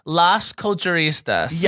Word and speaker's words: Las Culturistas: So, no, we have the Los Canistas here. – Girl. – Got it. – Is that how you say it? – Here's how you say it. Las Culturistas.